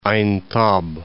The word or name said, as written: Aintab